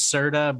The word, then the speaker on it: Serda: My last name's Serda, but it's spelled different, and so I always say like Serta mattress.